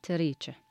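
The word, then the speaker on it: تريكه: طبعاً طبعاً ويهوس على تريتش، على شو سمعتوا صح؟ ت ر ي تش ه، ما هي كاف بس احنا ننطقها تشاء، بس انا عشان اسهل عليكم تنكتب كاف بس احنا ننطقها تشاء.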